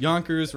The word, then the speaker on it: Yonkers: Yonkers rhymes with bonkers.